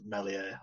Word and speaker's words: Meslier: That's the way Bryn Law pronounces it, because I think it's French, so it's pronounced Meslier or something like that.